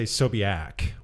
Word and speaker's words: Sobiak: My I say Sobiak with an A sound.